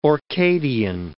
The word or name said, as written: Orcadian